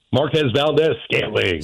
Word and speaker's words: Marquez Valdes-Scantling: Marquez Valdes-Scantling.